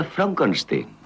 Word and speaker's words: Frankenstein: ¿Me toma el pelo? No, se pronuncia Frankenstein.